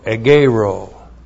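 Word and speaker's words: egeiro (ἐγείρω): E-G-E-I-R, long O, egeiro.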